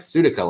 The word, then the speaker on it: Sutika: And I always tell them like Sutika, like the Phil Collins song, like Sutika.